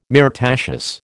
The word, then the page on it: myrtaceous: American English